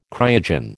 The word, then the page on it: cryogen: American English